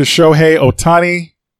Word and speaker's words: Shohei Ohtani: And congrats to Shohei Ohtani.